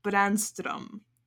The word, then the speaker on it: Brännström: And she informed me that apparently we have been pronouncing some names wrong. Apparently it is Brännström. That's how it's pronounced.